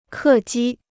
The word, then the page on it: 客机: kè jī